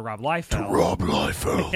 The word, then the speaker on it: Liefeld: It got interrupted for Onslaught and then Heroes Reborn, where they handed it over to Rob Liefeld. – Yeah. That's how it's pronounced, by the way.